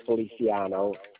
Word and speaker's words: Feliciano: R-A-U-L, pronounced Raul. – Mm-hmm. – Last name is Feliciano, F-E-L-I-C-I-A-N-O.